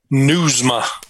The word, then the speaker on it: Nieuwsma: Nieuwsma. Just like you're reading news to your ma.